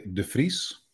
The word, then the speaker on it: de Vries: The Dutch in general will say de Vries. – Oh, I really butchered it. Okay. – But I will pronounce it as de Vries.